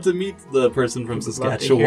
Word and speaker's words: Saskatchewan: But we have someone I want to shout out to in Saskatoon, Saskatchewan.